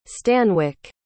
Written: stanwick

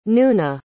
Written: nuna

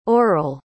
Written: orel

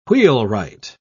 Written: wheelwright

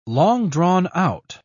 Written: long-drawn-out

